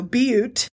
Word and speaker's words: Butte: And it's funny because I even typed it out phonetically, you know, Butte. And then I put. As in, boy, she's a beaut.